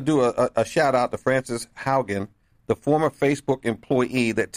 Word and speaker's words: Haugen: I think her name is pronounced Haugen, H-A-U-G-E-N.